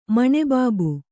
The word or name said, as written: ಮಣೆಬಾಬು